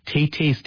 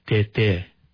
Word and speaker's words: Tay-Tay: it's spelled like Tay-Tay's Tales. Tay-Tay's Tales. And the woman who compiled all these stories, her name was Elizabeth DeHuff, and she worked at the Santa Fe Indian School at the time. But Tay-Tay, it means grandfather in the Tewa language, but it's not spelled correctly. It's not pronounced correctly. – Right. – It would actually be pronounced Tete, right? – Yes, you're yeah.